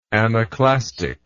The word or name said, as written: anaclastic